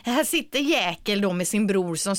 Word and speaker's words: Jäkel: måste ju uttalas Jäkel, inte jä Jäkel.